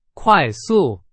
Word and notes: 快速: Chinese

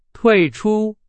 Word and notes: 退出: Chinese

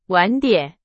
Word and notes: 晚点: Chinese

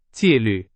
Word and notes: 纪律: Chinese

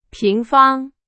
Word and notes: 平方: Chinese

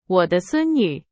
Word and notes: 孙女: Chinese

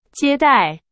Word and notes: 接待: Chinese

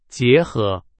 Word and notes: 结合: Chinese

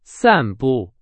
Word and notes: 散步: Chinese

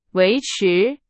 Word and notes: 维持: Chinese

